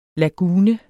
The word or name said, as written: lagune